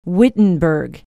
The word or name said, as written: wittenberg